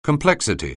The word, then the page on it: complexity: anglais